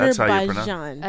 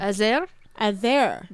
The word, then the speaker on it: Azerbaijan: That's how you pronounce it. – Azerbaijan. – Azerbaijan. – Bajan. – There you go.